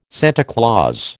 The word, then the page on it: Santa Claus: American English